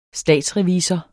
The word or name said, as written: statsrevisor